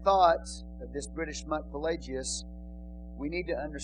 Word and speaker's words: Pelagius: The next one was given by a British monk, and his name is spelled P-A-L-A-G-E-A-S, Pelagius.